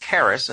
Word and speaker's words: charis: If the word grace is pronounced charis, and the word gift is simply charisma, from which we get the word charismatic.